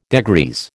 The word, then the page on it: degrease: American English